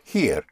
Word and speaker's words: hier: Oh, the word for here is the same as in English, here. It's spelled H-I-E-R, but here is here.